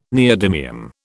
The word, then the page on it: neodymium: British English